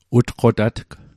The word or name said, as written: utqotatg